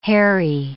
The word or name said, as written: harry